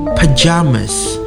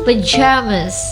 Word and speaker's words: pyjamas: Okay, pajamas. Bacanya sama. – Pajamas. – Pajamas. Cuman in British tulisannya PY. – Uh-huh. – PYJAMAS. – Okay. – Pajamas. – Uh-huh. – Kalau in American bacanya tulisannya PAJAMAS. Tapi bacanya sama.